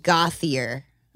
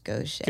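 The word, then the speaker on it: Gauthier: It looks like Gothier. – Oh, really? – Yeah, but it's pronounced Gaucher.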